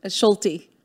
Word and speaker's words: Schulte: Schulte. – Schulte. You do say the E.